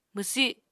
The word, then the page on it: mwsi: Apatani